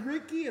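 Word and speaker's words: Rikki: My name is Ricky, and I spell my name gay.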